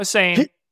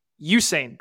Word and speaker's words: Usain: And it's Usain Bolt, just a reminder. Not Usain, Usain Bolt.